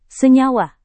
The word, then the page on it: senyawa: indonesian